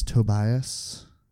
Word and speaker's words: Tobias: My name is Tobias. The S, there's a silent H in there. I don't know, something like that.